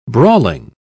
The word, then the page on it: brawling: British English